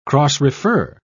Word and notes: cross-refer: British English

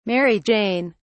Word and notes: mary jane: British English

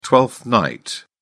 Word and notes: twelfth night: British English

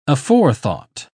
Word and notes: aforethought: British English

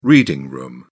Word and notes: reading room: British English

